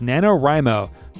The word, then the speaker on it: NaNoWriMo: So November is in some parts of the internet, which I'm very familiar with, is NaNoWriMo, which is how it's pronounced.